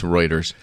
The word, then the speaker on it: Reuters: You know that the definition of an intellectual has been defined as somebody who can see the word Reuters and pronounce it correctly? – No, that's not true, is it? – Yeah, absolutely. – Yeah. Well, no. It's Reuters.